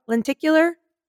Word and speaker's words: lenticular: thing, you get a free, I always say this word wrong, lenticular? – That's right. You said it right.